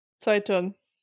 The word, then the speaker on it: Zeitung: Zeitung.